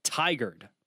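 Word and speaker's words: Tigard: I would like to thank Warren for tweeting me and telling me the correct pronunciation is Tigered.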